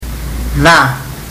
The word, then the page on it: ca: fiji